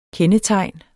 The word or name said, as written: KENDETEGNET